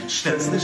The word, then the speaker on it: Szczęsny: Chesney, Chesney, Chesney, rei de Barcelona, té que merituro, té clac i fumador. – I ara ho fan en polonès, ben pronunciat. – Szczęsny, szczęsny, szczęsny to król Barcelony, Wojtek to emeryt, co po pani ci dał.